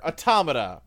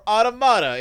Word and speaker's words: Automata: all right, the next game we are discussing is Nier Automata, or Automata, if you say it wrong, like they do in the video game.